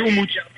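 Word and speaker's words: Umut: Attends, et juste comment on prononce ton nom en version originale? – Comme tu le dis, Oumout. – Et après, ton nom de famille? – Bozok, B-O-Z-O-K, Bozok.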